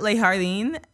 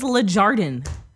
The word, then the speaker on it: Le Jardin: Well, did you just pronounce the J as an H? – Leigh Harding, like in Spanish. – No, it's French, first of all, and it means the garden. – Yeah. So do French pronounce their J's? – Yes.